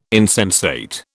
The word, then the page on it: insensate: British English